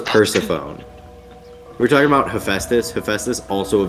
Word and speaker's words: Hephaestus: I thought it was Hephaust. I thought his name was Hephaust. – Mine is so much worse. – Okay. – What? – I thought it was like Hephaestius.